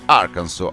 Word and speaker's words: Arkansas: decretando che la pronuncia ufficiale Arkansas alla S finale muta.